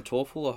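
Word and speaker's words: Taufel: And Simon Torfall, I hope I'm saying that right.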